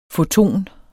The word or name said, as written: foton